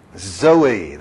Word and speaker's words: zoe: Zoe, that's how you pronounce the Greek word. You say, how do you know you pronounce it Zoe? Well, I know several ladies named Zoe in Greek-speaking places.